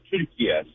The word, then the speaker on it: Türkiye: The way Turkish people pronounce it is actually the way it's said, Türkiye.